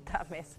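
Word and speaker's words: Thames: No, está castellanizado, sí, Tames.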